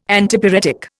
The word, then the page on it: Antipyretic: Indian